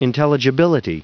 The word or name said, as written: intelligibility